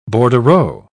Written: bordereau

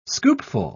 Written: scoopful

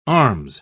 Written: arms

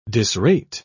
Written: disrate

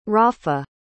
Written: rafa

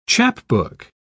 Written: chapbook

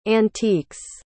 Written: antiques

